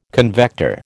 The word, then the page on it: convector: American English